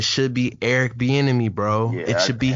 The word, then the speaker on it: Bieniemy: It's Eric Bieniemy.